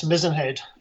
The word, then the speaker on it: Mizen: So another lighthouse where you were stationed on the south coast of Ireland was Mizenhead, M-I-Z-E-N, is that, do you pronounce it Mizenhead? – Yes, Mizenhead, like the mast on a ship.